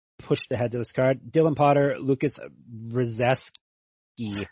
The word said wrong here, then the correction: Brzeski: Your pronunciation of Polish names is always gets me. It's Brzezki. – Brzezki.